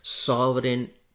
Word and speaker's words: sovereign: Friend, कुछ समय से अखबारों में आप एक शब्द पढ़ रहे होंगे, वो है सॉवरेन बॉन्ड। पढ़ने हिंदी में भी आपको यही शब्द पढ़ने को मिल रहा होगा। सॉवरेन की स्पेलिंग है SOVEREIGN, सॉवरेन बॉन्ड, है ना।